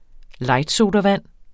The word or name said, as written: lightsodavand